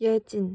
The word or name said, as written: yejin